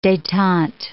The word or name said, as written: detente